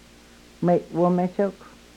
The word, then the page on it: mewomechok: Yurok